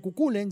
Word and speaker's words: Cuchulain: Así pueden nombrar al guerrero mítico Cuculain, se escribe Cuchulain, pero se pronuncia Cuculain, ya van a escuchar en la letra si prestan atención.